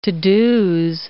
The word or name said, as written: to-dos